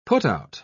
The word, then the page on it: putout: British English